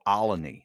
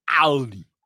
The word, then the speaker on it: Olney: But the people from Philly go Olney. They put an extra syllable in there. – Olney.